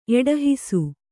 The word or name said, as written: ಎಡಹಿಸು